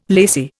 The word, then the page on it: lacy: Indian